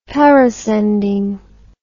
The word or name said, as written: parascending